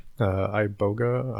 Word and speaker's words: iboga: Iboga, I-B-O-G-A.